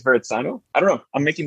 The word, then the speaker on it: Verrazzano: I would think with a double Z, does mozzarella have two Z's? So maybe Verrazzano. – Verrazzano.